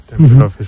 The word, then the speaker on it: Tempelhof: Tempelhof es un poco.